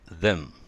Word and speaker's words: them: だから、ゼムじゃなくて them。